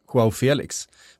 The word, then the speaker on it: João Felix: Ja, för alla som då inte spelar Football Manager och vet allt om João Felix, så det är säkert helt korrekt uttal förresten. João Felix. Med en liten så här östgötsk touch i efternamnet. – Ja, absolut. Och inte Felix eller något sånt där.